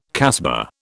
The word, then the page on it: casbah: British English